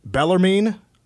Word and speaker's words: Bellarmine: And then you have back-to-back non-con games at home against Bellarmine.